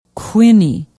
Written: quinney

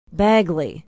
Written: bagley

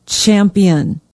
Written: champion

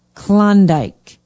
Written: klondike

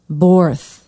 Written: borth

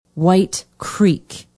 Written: white creek

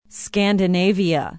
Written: Scandinavia

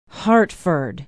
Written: hartford

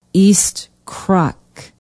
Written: east krok

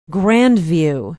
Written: grandview

